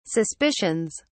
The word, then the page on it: suspicions: British English